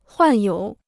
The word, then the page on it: 患有: huàn yǒu